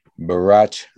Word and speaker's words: Bharat: Joining us now from the National Economic Council, Bharat Ramamurthy. Bharat, how are you, sir? – I'm doing very well, thank you. – Did I pronounce your name correctly? – That was great. – Bharat rhymes with Barack.